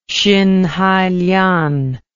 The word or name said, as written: xinhailian